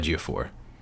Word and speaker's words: Ejiofor: Ejiofor.